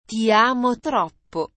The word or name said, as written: ti amo troppo